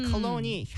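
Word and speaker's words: cologne: 네, 컬러니 향수잖아요. – 네. 네, 이거 정확하게 우리 스펠링 한번 짚어줄까요? – 네, C-O-L-O-G-N-E 이거입니다. 되게 G-N인데 왜 컬러니 이렇게 되는지.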